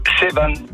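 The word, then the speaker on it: Sevan: On le prononce comment? – Sévan.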